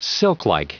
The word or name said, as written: silklike